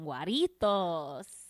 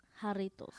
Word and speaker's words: Jarritos: Jarritos. – Jarritos, like Jaime, because the J in Spanish sounds like an H, but I don't know why I've always called it hot.